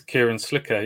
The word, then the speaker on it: Kieran Slicker: Kieran Slicker? Is that how you pronounce it? – It's Kieran Slicker, yeah.